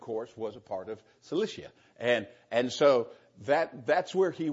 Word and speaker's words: Cilicia: Then I went into the regions of Syria and Sicilia. How do you say that? I get things in my mind, then I can't say it. Say it again. Cilicia. I knew that.